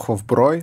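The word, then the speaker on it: Hofbräu: Сколько ящиков, сколько ящиков Хофбрау, которые, на самом деле, по-моему, Хофброй. Хофброй.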